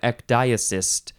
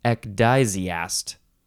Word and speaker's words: ecdysiast: you are an ecdysiast. No, ecdysiast. Ecdysiast.